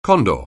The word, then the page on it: condor: anglais